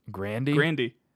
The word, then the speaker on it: Grande: That's how her last name is legitimately pronounced. – Grande.